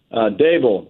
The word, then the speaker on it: Daboll: I've heard your last name pronounced Daboll or Daboll. What is the right pronunciation? – Daboll, like a table? – Yes.